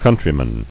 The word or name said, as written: Countrywomen